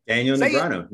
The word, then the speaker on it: Negreanu: Daniel Negreanu.